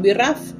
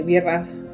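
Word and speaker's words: BRAF: Que es la mutación Birraf. Se escribe B larga R A F, Birraf.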